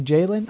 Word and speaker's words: Jaylen: Let's talk about the Celtics first with Julian Brown. Or am I saying his name? I think it's Jaylen. It's actually Jaylen. I've been saying it wrong this whole time.